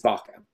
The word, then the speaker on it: Vaca: I'm partially Mexican, which most people don't realize. So the Spanish pronunciation is Vaca.